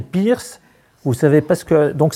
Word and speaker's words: Peirce: Je dis Peirce, mais ça s'écrit d'une façon différente.